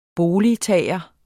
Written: boligtager

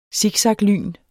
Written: siksaklyn

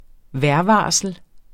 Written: vejrvarsel

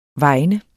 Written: vegne

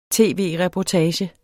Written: tv-reportage

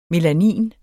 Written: melanin

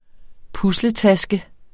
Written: pusletaske

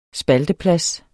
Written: spalteplads

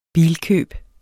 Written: bilkøb

